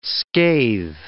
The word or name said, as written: scathe